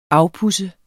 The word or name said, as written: afpudse